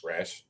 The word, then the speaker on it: Rausch: Rush? – Rash? – Rash? – Rash. Like you have an itchy red spot.